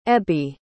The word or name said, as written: ebbie